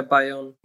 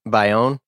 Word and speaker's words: Bayonne: Is it B-A-I? How do I say that word? I'm sorry. – Oh yeah, Bayonne. – Bayonne?